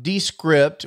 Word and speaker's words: Descript: And the thing that was interesting about Descript, which is how they pronounce it, by the way, they seem to put the emphasis on the first syllable.